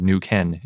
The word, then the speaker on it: Neuquén: and I think I've been pronouncing Neuquén wrong. It's spelled N E U Q U E N with an accent over the E. – Yeah, but I found a pronunciation guide that said New Ken is how you say it.